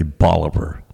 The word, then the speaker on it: Bolivar: Actually, it's technically Bolivar, named after Simon Bolivar. But in West Virginia, we pronounce things, apparently, a little differently than some.